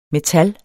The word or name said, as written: metal